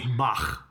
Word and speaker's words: Bach: Bach.